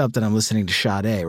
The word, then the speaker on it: Sade: I think it's like Sade, but you do it with like you're like not paying attention to the fact that you're pronouncing a sh at the beginning. It's like Sade. It's a quick sh.